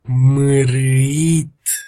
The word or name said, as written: marait